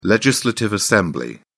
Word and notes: legislative assembly: British English

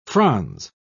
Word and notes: frons: British English

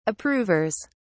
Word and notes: approvers: British English

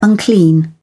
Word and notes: unclean: British English

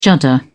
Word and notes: judder: British English

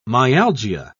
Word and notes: myalgia: British English

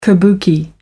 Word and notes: kabuki: American English

